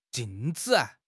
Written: jing4 zi5

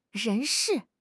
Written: 人士

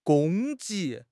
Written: 攻击

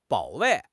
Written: 保卫

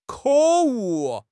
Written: 可恶

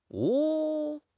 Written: mo2

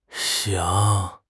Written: xing3

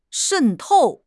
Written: 渗透

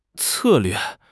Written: 策略

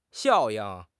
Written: 效应

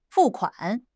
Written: fu4 kuan3

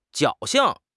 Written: 侥幸

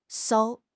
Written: 艘

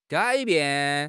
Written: gai3 bian4

